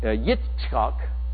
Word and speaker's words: Yitzchak: And Yitzchak is the name of their son, Isaac.